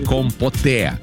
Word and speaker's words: компоте: Так вот, вот это все три слова заимствованы из французского языка, поэтому ударение в них падает на последний слог. То бишь «конфи», «кули», еще есть «компоте».